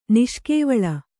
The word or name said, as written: ನಿಷ್ಕೇವಳ